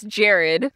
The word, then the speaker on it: Jarred: So it starts off and you meet Jared, who, his name is spelled J-A-R-R-E-D, and I always want to read it as Jarred. But it's Jared.